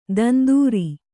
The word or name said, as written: ತಂದುರಿ